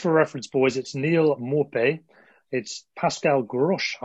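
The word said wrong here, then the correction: Groß: I think that's right, isn't it, David? – No. It's Gross. – No? – Yeah. – It's Gross. Gross.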